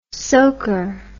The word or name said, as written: soaker